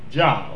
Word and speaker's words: Jah: Here, the Hebrew word for God is Jah, the capital L-O-R-D, in capital letters. It's pronounced Jah, or it's spelled Jah in the Hebrew, J-A-H.